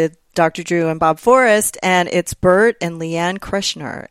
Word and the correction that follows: Kreischer: And that's how you say it, right? – Kreshner. Doesn't matter. – What? Kreischer? Oh, yeah. – Okay. It's Bert and Leanne Kreischer.